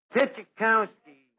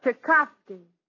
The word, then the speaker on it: Tchaikovsky: Debussy, Puccini, Bach, Tchaikovsky. – Archie, that's Tchaikovsky. – So I mispronounced one of them.